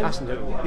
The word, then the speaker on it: Hasenhüttl: Hasenhüttl.